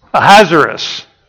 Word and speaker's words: Ahasuerus: Ahasuerus. Yeah, I was putting an extra syllable in there, wasn't I?